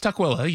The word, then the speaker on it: Tukwila: I mean, can anyone even pronounce Tukwila? Yeah, well, it's Tukwila. You had a weird pronunciation, but it's Tukwila.